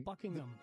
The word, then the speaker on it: Buckingham: Eso sí, y de hecho, inglés perfecto, el H medio que se es medio silencio. Buckingham. Buckingham. – Más House. – O menos. – No se pronuncia mucho.